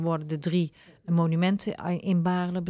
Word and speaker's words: Baarle: Zeg ik het goed? – Baarle. Stichting Herdenking Baarle. – Ja.